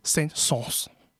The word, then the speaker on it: Saint-Saëns: Da heißt der Camille Saint-Saëns. – Also theoretisch mit den zwei Doppelpunkten über dem E wird das A und das E separat ausgesprochen, wie bei dem Wort Noël, Weihnachten.